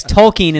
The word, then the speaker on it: Tolkien: Yes, Tolkien is the correct pronunciation.